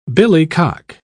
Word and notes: billycock: British English

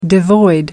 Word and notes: devoid: American English

